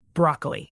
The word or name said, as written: broccoli